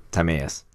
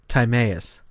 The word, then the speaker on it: Timaeus: It's spoken of in Plato's Timaeus, which... Did I say that right? – I'm pretty sure it's Timaeus. – Timaeus. Look at that.